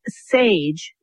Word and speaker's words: Saje: So today's ensembles will range from the Glenn Miller Modern Airs from the 1940s to the group called Sage, which is spelled S-A-J-E,